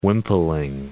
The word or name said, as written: wimpling